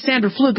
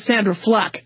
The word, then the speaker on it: Sandra Fluke: You know, it's not pronounced Sandra Fluke. It's pronounced Sandra Fluck.